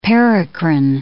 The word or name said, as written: paracrine